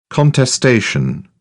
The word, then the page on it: contestation: British English; ɒ